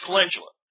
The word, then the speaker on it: calendula: Um, I've heard people pronounce it calendula, which I think it's spelled a little close to that, but it's calendula.